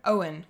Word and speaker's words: Eoin: And the author's name was Owen Colfer. It's an Irish name, so because it's spelled E-O-I-N.